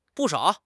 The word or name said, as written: bu4 shao3